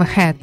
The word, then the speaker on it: H.E.A.T.: Это группа под названием Head. Ну, пишется как H, E, A, T, четыре буквы.